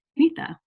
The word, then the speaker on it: Neeta: Neeta, welcome to the program.